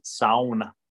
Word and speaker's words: sauna: And you gotta pronounce it sauna. – Yeah, that's what John told me. It's sauna.